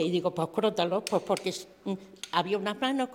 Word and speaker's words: crótalo: Se pronuncia así, algo que a mí me gusta aprender. – C-R-O, tal y como... – O-T-A-L-O. Crótalo. – Crótalo. – Vale, sí, perfecto. Ya no se me olvide. Con tilde. El lado, claro. Es esdrújula, ¿no?